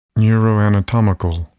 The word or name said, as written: neuroanatomical